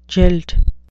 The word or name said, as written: jilt